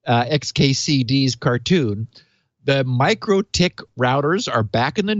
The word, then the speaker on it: MikroTik: Okay, so MikroTik is the way you're supposed to pronounce this. But I really did like pronouncing it Microtik because it sounds so awful.